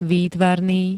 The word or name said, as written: výtvarný